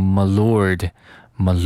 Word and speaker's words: milord: 这个 M I L O R D 我们读成 my lord，my lord。